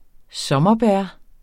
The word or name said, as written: sommerbær